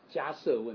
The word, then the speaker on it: 迦叶: 啊，大宝基金里面这部经里面有一品啊，叫做迦摄问品。迦摄佛就是这个 Kashyapa，前面第一个字 Kashyapa，迦是释迦牟尼佛的迦，摄其实是叶子的叶，啊，念成摄，就是我们一般说迦叶佛啊，其实是迦摄佛了，迦摄。